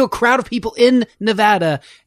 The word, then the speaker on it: Nevada: Don't you dare say Nevada in front. It's Nevada, for sure. – Okay. – And the best way to remember it is Nevatitude.